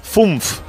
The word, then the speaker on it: fünf: para que os vayáis preparando 5 se dice FUNF.